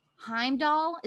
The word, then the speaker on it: Heimdall: Ooh, okay, so your options are Loki, Heimdall, is that how you say it? – Yes.